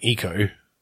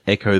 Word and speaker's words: Ecco: Eco the Dolphin were all like, I mean, it's called fucking Eco. Eco-friendly, right? – Wasn't it Echo the Dolphin? As in ECCO? – Well, I always pronounce it Eco, but it could have been Echo.